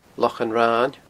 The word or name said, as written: Lochan Rain